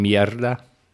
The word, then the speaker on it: mierda: If I were to say it in Spanish, it would be culo y merda. – You mean mierda?